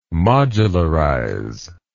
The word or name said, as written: modularize